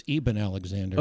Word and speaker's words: Eben Alexander: Is it pronounced Eben Alexander or Eben Alexander? – I think it's Eben Alexander.